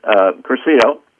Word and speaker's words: Cursillo: Curcio, C-U-R-S-I-L-L-O, and it means short course in Spanish. Some of our weekends, so we call our weekends a short course in Christianity. Mm. Curcio